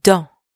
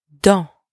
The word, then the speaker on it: dans: All right, so our first preposition of place is dans, meaning in. Dans. Dans. And careful here, don't pronounce the S at the end, okay?